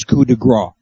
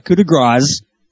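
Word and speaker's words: coup de grâce: The proper pronunciation is coup de grace, not coup de grace.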